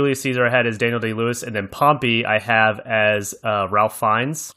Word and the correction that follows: Ralph: Oh, yeah, Rafe. You always say that wrong. It's Rafe.